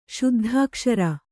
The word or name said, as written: ಶುದ್ಧಾಕ್ಷರ